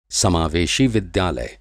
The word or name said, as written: समावेशी